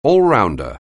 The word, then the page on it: all-rounder: English